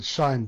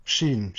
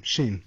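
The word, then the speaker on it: Shein: is it Shine, the Chinese Amazon equivalent? – Sheen. – Sheen.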